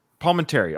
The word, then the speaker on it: Palminteri: Palminteri.